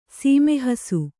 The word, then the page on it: ಸಮ್ಮೋಹಿಸು: sammōhisu